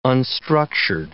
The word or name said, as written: unstructured